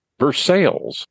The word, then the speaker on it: Versailles: Well, we're going to go to some place that spells its name the same as Versailles, but it's pronounced Versailles, and it's in Ohio.